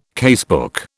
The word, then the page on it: casebook: British English